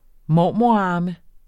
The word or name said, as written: mormorarme